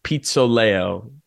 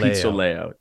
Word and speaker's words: Pizzoleo: it's pronounced Pizzoleo. So Luca Pizzoleo. Pizzoleo.